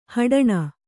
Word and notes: ಹಡಣ: haḍaṇa

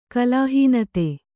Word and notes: ಕಲಾಹೀನತೆ: kalāhīnate

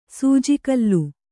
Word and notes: ಸೂಜಿಕಲ್ಲು: sūji kallu

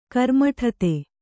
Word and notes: ಕರ್ಮಠತೆ: kannada

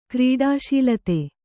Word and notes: ಕ್ರೀಡಾಶೀಲತೆ: kannada

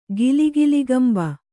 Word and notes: ಗಿಲಿಗಿಲಿಗಂಬ: gili giligamba

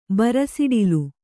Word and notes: ಬರಸಿಡಿಲು: bara siḍilu

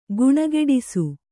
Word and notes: ಗುಣಗೆಡಿಸು: guṇageḍisu

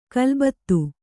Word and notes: ಖಲಬತ್ತು: Kannada; khalabattu

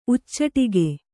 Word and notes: ಉಚ್ಚಟಿಗೆ: kannada

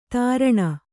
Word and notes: ತೀರ್ಣ: kannada

